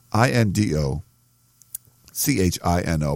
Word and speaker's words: Indochino: So I N D O C H I N O, pronounced Indochino.